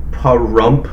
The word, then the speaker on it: Pahrump: All right, well, phonetically, Pahrump, Nevada. – Pahrump, Nevada is the best we can describe it as.